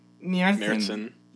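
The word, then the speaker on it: Märzen: How do you say Marzen? – Mjertsen. – Mjertsen. – That's how it's pronounced.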